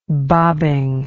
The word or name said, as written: bobbing